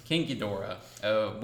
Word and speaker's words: King Ghidorah: I say his name wrong all the time. I have to fix that. King Ghidorah.